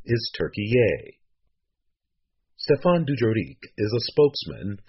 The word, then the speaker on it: Türkiye: Türkiye is how the country's name is pronounced in Turkish.